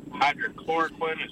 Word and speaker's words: hydroxychloroquine: I was wondering, while you're on this topic of the hydroxychloroquine, is that how you say it? – That's correct.